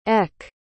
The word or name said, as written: eck